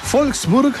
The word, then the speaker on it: Wolfsburg: Saludamos a Siegfried desde Wolfsburg, Alemania. No, es Wolfsburg, porque la W se pronuncia como V corta, ¿no? O V, ¿no? Se dice V.